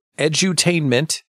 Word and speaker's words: edutainment: edutainment.